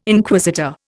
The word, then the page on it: Inquisitor: Indian